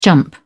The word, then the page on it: jump: British English